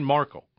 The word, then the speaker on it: Markle: So, since the royal wedding, Prince Harry and Meghan Markle, and I keep pronouncing her name Merkel. I don't know why. Meghan Markle.